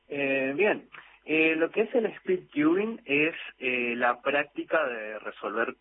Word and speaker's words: Speed Cubing: ¿sos también el creador de la agrupación misionera de Speed Cubing? ¿Así lo dije bien? – Sí, está bien, sí.